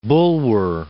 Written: Bulwer

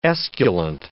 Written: esculent

